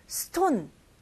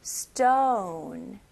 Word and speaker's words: stone: 그리고 여기서는 우리나라 발음으로 오 발음보다는 조금 더 길어요. 그리고 제 입모양 보세요. 이거는 stone, 이게 아니고요. stone, 네, 입이 동그랗고 뭔가 커지다가 또 다시 작아진 느낌이죠? 그래서 stone. stone 이렇게 발음하시면 좋아요.